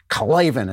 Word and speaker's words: Klavan: Put in Klavan. And you got to say it like that. Klavan is K-L-A-V-A-N.